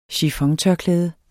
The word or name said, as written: chiffon